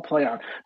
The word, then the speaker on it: PLAION: Sorry, it's called Play On, but it's spelled stupid. It's spelled PLAION. Obviously because they need to trade something, so it's called Play On, but it's spelled dumb.